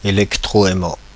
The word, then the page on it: électroaimant: [e.lɛk.tʁɔ.ɛ.mɑ̃]; Paris